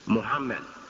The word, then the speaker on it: Muhammad: So I still choose to use that spelling, M-O-H-A-M-M-E-D. But I tell people who make the mistake of saying Mohammed, I tell them, I say, no. I say, it's still the same pronunciation, Muhammad. And if they have trouble still, I say, you know Muhammad Ali. I say, still, the pronunciation is Muhammad.